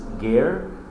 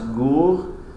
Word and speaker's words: Ger: In Eretz Yisrael, the largest Hasidus is Ger, in Hebrew pronounced Gur, Ger Hasidim, Hasidei Gur, followed by Vizhnitz, Chabad, Belz, Satmar, Breslav, Nadvarna, Toldos Aron.